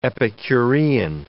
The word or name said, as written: epicurean